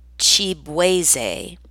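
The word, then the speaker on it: Chibueze: A play on his surname, which is pronounced Chibweze, the moniker came about after it was mispronounced by his high school football coach.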